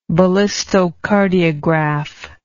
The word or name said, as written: ballistocardiography